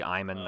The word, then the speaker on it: Aemon: It should be Iman, not Aemon.